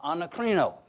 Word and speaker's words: anakrino: phonetically as we spell A-N-A-K-R-I-N-O, anacrino.